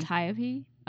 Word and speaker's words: Antiope: Antiope.